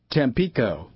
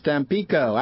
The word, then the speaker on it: Tampico: Tampico. – Oh, doesn't matter. – That's how the Mexicans pronounce it. It is Tampico. Actually, I think people in English pronounce it that way, too.